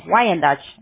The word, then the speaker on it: Wyandotte: Por ejemplo, Miguel, yo sé que ustedes están en Wyandotte, ¿así se pronuncia? – Sí.